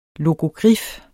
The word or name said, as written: LOGOGRIF